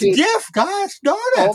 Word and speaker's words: GIF: You know, it's pronounced GIF. It's graphic with a hard G. It's not GIF, like as if it's giraffic.